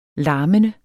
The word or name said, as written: larmende